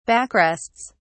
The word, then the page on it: backrests: British English